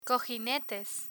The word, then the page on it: cojinetes: spanish